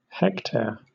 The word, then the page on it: hectare: British English